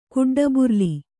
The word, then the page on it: ಕುಡ್ಡಬುರ್ಲಿ: Kannada